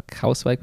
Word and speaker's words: Kruiswijk: Van Baarle und Kruiswijk haben sich, Krauswijk wird da ausgesprochen, tatsächlich.